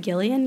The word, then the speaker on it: Gillian: I want to say she pronounces it Gillian, yes.